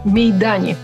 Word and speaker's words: Мейдани: 80 исполняется албанскому физику и президенту Рейджепу Мейдани. Так поставлено ударение, я бы ударила к другому.